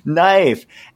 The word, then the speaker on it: knife: you don't pronounce the K, it's knife.